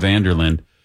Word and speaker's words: Vanderlinde: Could be Vanderlinde, but I'm going with Vanderlinde. It's a silent E there.